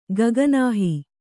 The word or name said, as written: ಗಗನಾಹಿ